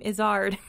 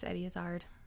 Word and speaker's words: Izzard: Fun fact, he actually pronounces his last name Izzard.